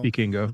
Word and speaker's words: Vikingo: So then we got Chris Sabin and Kushida versus El Hijo del Vikingo, or Vikingo, is that how you say it? Vikingo? – Vikingo.